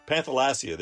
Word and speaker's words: Panthalassa: Panthalassa, there we go.